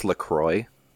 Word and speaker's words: LaCroix: It's pronounced LaCroix and it's a sparkling water.